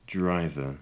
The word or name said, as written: driver